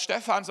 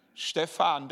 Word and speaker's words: Stefan: die Englisch sprechen, die können das gar nicht aussprechen. Stefan, da, da, brechen die sich die Zunge äh, und das will ich nicht.